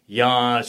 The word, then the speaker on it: Jan: All right, I'll say Jan, spell J-A-N, pronounce like Y.